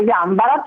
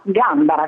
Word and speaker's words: Gambara: Se proprio qualcuno avesse un dubbio, può andare sul dizionario RAI di ortografia e pronuncia e alla voce Gambara troverà Gambara con l'accento sulla prima A.